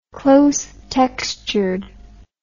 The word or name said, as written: close-textured